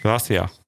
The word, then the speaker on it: Gracias: Zum Beispiel, wenn du jetzt auf Danke heißt auf Spanisch Gracias, das S würdest du hier niemals aussprechen in Andalusien. Das wird einfach verschluckt. – Also dann heißt es Gracias.